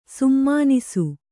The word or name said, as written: ಸುಮನಸ್ಸು